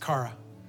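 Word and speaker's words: chara (χαρά): But listen, as I was studying to get ready for this, I learned and I come across that the Greek word for joy is chara, C-H-A-R-A, chara, kara. Some people pronounce it kara. You know what the Greek word for grace is? It's charis or charis, C-H-A-R-I-S.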